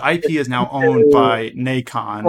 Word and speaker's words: Nacon: Nacon. Nacon. – Pronounced Nacon. So like bacon. – Like imagine a horse convention. It's Nacon.